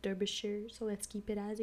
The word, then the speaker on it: Derbyshire: It's Derbyshire.